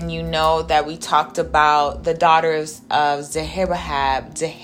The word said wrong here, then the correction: Zelophehad: Lord, I don't even know how to pronounce it. Zelophehad.